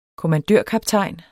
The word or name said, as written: kommandørkaptajn